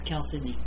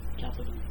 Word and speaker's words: chalcedony: And it's chalcedony. – Chalcedony? – Instead of chalcedony.